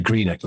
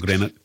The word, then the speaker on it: Greenock: Well, you have pronounced Greenock properly, which is always a good thing. We hear some from down south calling it Greenock, not Greenock.